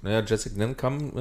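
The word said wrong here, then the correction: Ngankam: äh, ich kann den Namen nicht richtig aussprechen. – Gankam. – Gankam.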